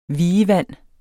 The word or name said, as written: vievand